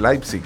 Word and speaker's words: Leipzig: Contra el Leipzig, sería el Leipzig, es la pronunciación que le he escuchado. De Michelis dirige el Leipzig, del fútbol alemán. Así que, por eso digo Leipzig, porque he escuchado que lo pronuncian así.